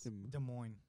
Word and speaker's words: Des Moines: Dubois? – Des Moines. Moines. How do you say this? – Des Moines.